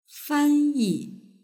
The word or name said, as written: fanyi